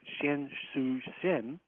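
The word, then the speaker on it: Qian Xuesen: What we have in China is someone who played a similar role to von Braun, and his name is Dr. Xian Shu Shen. And there are different spellings for that, but that's basically the pronunciation using my...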